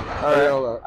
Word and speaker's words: Arriola: I say your last name right? – Yeah, Arriola. – Arriola.